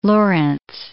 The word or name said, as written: Lorenz